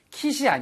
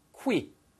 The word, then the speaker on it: quit: 예, 발음 주의하세요. 절대 quit이 아니고요. quit. quit. 입술이 나갔다가 들어오셔야 돼요. 그래서 quit. quit. quitting. 어? 쌤, 왜 그렇게 발음해야 되나요? 사전을 찾아보시면 Q 발음 다음에 나오는 모음이 있을 때는요. 거의 99.9% 다 W 발음이 있습니다.